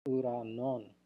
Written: ouranwn

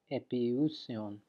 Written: epiousion

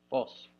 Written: fos

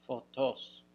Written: fotos

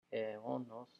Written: eonos

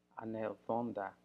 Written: anelthonda

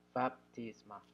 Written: baptisma

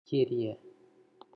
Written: Kurie

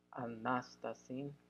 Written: anastasin

